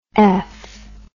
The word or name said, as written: eff